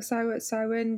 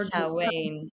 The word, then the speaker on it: Samhain: It's also the Samhain, kind of Samhain, is that how you say it? Samhain, kind of. – Yeah, Samhain is another. – Yeah. – Transition. Samhain is another word that people can't say based on how it's spelled.